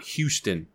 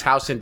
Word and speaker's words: Houston: It's not Houston. – Which I never Street. – Understood. – It's Houston. Doesn't make any fucking sense, but it's Houston Street. – But okay. It's spelled like Houston.